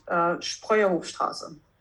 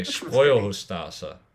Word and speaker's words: Spreuerhofstraße: Now here you're just, I'm going to slaughter this, but the world's narrowest street is in Reutlingen and it's called the, Sprauerhofstrasse. – How was that? – So it's Reutling, you pronounce that quite well, and the street is called Spreuerhofstraße. – Okay, Spreuerhofstraße.